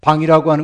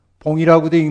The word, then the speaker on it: 방: 방이라고 하는 건 뭐예요? 봉이라고도 읽는 글자인데 곤봉할 때 그 봉자입니다. 그 방해야, 불교에서는.